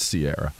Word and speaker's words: Ciara: Was it like Sierra Mist? – It's spelled CIARA. You could say it. It's spelled that way, but it's CIARA.